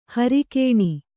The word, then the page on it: ಹೊರಗಣ: kannada